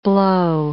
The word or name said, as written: blow